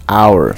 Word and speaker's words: our: Our. Parece a pronúncia de hora em inglês, mas não tem um H.